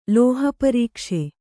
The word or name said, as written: ಲೋಹಪರೀಕ್ಷೆ